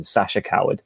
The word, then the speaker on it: Sacha Coward: So it's S for sugar, A-C-H-A, Downspace, C-O-W-A-R-D, like the cowardly lion, Sasha Coward.